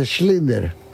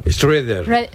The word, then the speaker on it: Schrödinger: Schrödinger. – Schrödinger. Schrödinger. – Schrödinger. – Qué muy difícil pronunciar. – Lo pronuncia tan mal que tú no puedes, no sabes ni a qué se está refiriendo.